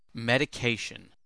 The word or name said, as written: medication